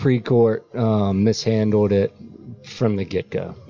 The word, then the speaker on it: Precourt: So his last name is pronounced like it is. So. – Yeah, Precourt, yeah.